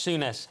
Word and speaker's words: Souness: Now, apparently I mispronounced his name earlier. Graham Souness. Souness? Yes? – Souness.